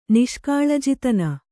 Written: ನಿಷ್ಕಾಳಜಿತನ

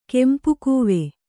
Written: ಕೆಂಪುಕೂವೆ

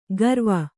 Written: ಗರ್ವ

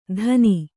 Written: ತನಿ

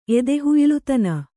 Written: ಎದೆಹುಯ್ಲುತನ

